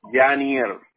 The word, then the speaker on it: Yanier: D-A-M-I-E-N. Okay. – No, Yaniel. – Oh. – Se escribe genio, genio. – ¿Con Y? – Sí. – Genier. Y-A-N-I-E-R.